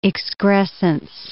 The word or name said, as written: excrescence